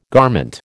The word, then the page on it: garment: American English